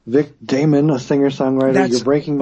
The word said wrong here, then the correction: Damone: Say his name again. – Vic Damon or Damone? – Yes, Vic Damone.